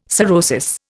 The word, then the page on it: sorosis: Indian